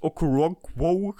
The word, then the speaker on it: Okoronkwo: Mit, mit, mit Smith und Okoronkwo, heißt er so, ja? Ist das richtig ausgesprochen, viele? – Ja, ja.